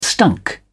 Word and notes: stunk: British English

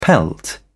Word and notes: pelt: British English

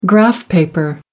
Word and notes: graph paper: American English

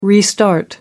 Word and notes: restart: American English

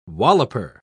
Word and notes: walloper: British English

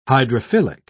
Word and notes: hydrophilic: British English

